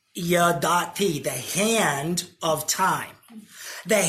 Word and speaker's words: yadati: The Hebrew is yadati. That's how you say it.